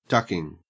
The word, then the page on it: ducking: Australian English